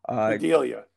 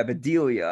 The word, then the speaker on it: Bedelia: Am I saying that right? Or Bedelia? – Bedelia. – Yeah, Bedelia. I'm sorry.